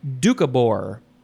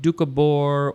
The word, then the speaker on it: Doukhobor: The next word is doucabor. I think that's how you pronounce it. Doucabor. It is spelled capital D-O-U-K-H-O-B-O-R, or you can take out the very first O.